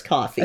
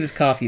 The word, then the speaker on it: Ko-fi: I always pronounce it wrong. I think it's coffee. – I think it is coffee.